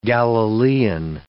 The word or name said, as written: Galilean